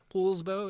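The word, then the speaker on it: Poulsbo: Polsbo, is that how you say it? Everybody says Paul, but it's not Paul.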